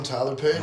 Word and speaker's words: Tyler Paden: I'm Tyler Paden.